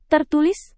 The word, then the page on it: tertulis: Indonesian